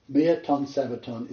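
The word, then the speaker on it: Mia ton sabbaton (μία τῶν σαββάτων): Because there are eight places in the New Testament, I'm not going to go there, where miaton sabbaton is translated the first day of the week.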